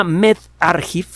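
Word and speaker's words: medRxiv: Bueno, en, eh, el sistema MedArchiv, se escribe MedRXIB, la B es V, es B chica, y la I es I latina.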